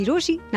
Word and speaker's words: Rochie: मेरा नाम है मिर्ची रोशी, नाम की स्पेलिंग है ROCHIE, and while you find me on social media, कहीं मत जाना क्योंकि वापस तो यही है आना.